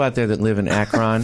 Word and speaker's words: Akron: Pronouncing your city correctly, which is Akron.